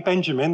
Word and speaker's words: Benjamin: So, in fact, my surname, Benjamin, that name that rhymes with the other two, it came from their conversion.